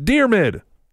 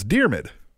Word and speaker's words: Diarmuid: He says, P.S., it's pronounced Dearmid. Silly nerds. Hashtag tweet the table. Yeah. Thanks, though. That's what I was asking for, is exactly how to pronounce it. So it's Dearmid. D-I-A-R-M-U-I-D. How could I not get that?